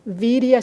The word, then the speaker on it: virya: O sea, que prefiero usar el término de virya. Virya se escribe V-I-R-Y-A, virya.